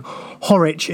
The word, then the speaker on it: Horwich: Um, thanks to uh, Michael, whose uh, Twitter handle he he has uh, messaged us saying for clarification, Horwich is pronounced the same way as Norwich, and I think yeah we've been getting that wrong.